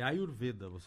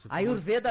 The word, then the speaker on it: Ayurveda: É Ayurveda. – Ayurveda falou mesmo. – Isso mesmo. – Eu nunca sei se é o E aberto ou é fechado, então é Ayurveda.